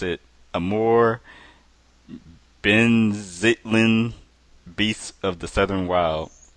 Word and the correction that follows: Zeitlin: I know I butchered that name. – That's Zeitlin. – Zeitlin.